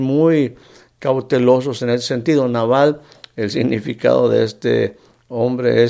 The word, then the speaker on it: Nabal: El, nombre, el nombre de, de Nabal, y varias ocasiones lo, he pronunciado incorrectamente, es Naval, solamente con una A.